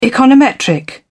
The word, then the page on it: econometric: British English